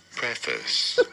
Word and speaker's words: preface: Preface. You do want to stress on the first syllable.